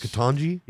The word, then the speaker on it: Ketanji: President Biden has nominated Ketanji. Am I saying that correctly? – Yes. – Ketanji? – Yes.